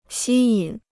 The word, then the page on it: 吸引: xī yǐn